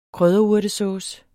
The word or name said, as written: krydderurtesauce